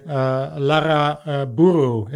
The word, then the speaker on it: Larraburu: I'm here with Veronica, and I hope I pronounced the last name okay. Larraburu, is that correct? Or oh, you can roll your R's very well. – Yeah, I'm Dutch. – Yes, what that's perfect.